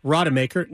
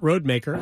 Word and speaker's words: Rodemaker: I realize now that I've been mispronouncing Tate's name, Rodemaker, not Roadmaker. I don't know.